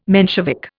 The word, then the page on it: Menshevik: Indian